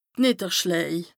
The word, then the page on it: d' Nìdderschläj: Bas-Rhin; Strasbourg